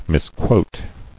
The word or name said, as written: Misquotation